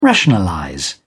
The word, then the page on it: rationalize: British English